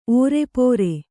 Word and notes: ಓರೆಪೋರೆ: ōrepōre

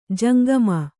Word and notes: ಜಂಗಮ: kannada

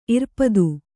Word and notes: ಇರ್ಪದು: irpadu

